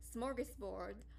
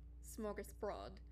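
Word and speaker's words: smörgåsbord: Mind you, in movies like Charlotte's Web, it was pronounced smorgasbord. But it's actually pronounced smorgasbrod.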